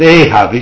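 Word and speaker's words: Ahab (אהב): The first time the word for love, the word's pronounced Ahab, it's spelled A-H-A-B in Hebrew, but it's pronounced Ahab.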